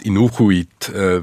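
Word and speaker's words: Inughuit: Oder als Inukuit, wie sich jetzt die Nordgrönländer selbst bezeichnen, so spricht man das aus.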